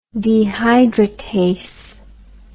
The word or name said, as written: dehydratase